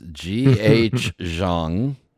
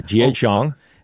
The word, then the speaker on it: G.H. Zhang: This is, and I'm going to mispronounce it, and go ahead, everybody laugh at me, but it's G.H. Zhang. – That's correct, yeah, G.H. Zhang.